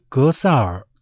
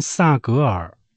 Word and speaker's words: 格萨尔: 中共领导习近平在人大会议闭幕式致辞时，不但将西藏史诗《格萨尔王》归为中华文明，更将格萨尔错念成萨格尔。